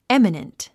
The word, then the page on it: eminent: émənənt; エミナント